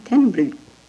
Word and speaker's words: Tenryu: What do you do with a U? You don't pronounce him. Tenryu.